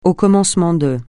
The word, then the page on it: au commencement de: francais